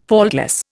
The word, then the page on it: faultless: Indian